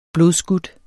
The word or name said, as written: blodskudt